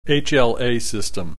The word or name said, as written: HLA system